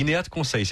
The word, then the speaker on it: INEAT Conseil: INEAT Conseil, c'est bien comme ça qu'on la prononce.